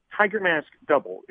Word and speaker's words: Tiger Mask W: Well, first off, the show is called Tiger Mask Double. It's spelled Tiger Mask W, but it's pronounced double because there are two Tiger Masks.